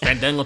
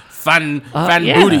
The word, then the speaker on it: Fandango: It was a grudge because Jericho purposely butchered Fandango's name, which Fandango took offense to for some reason. It's not Fan Booty. Yeah. It's Fandango.